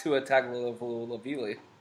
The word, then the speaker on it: Tua Tagovailoa: Well, besides Tua Tagovailoa.